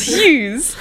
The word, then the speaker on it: Hughes: How do you say it? Is it Hoogees? And I thought I thought it was Holes. And I was like, excuse me, it's Hughes.